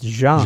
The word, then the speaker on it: Jean: Because Gene in English sounds like it's a woman's name, whereas in French it's Jean. – Jean, yeah.